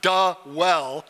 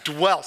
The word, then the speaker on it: dwell: It's one syllable, dwell, right? It's not duh-well. It's dwell.